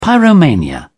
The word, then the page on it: pyromania: British English